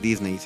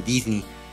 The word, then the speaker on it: Disney: que no se dice Disney, se dice Disney.